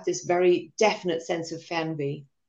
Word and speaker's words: Fernweh: So it's called Fernweh.